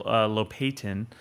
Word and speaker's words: Lopatin: And I had Daniel Lopatin, if that's how you pronounce his name.